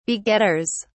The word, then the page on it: begetters: British English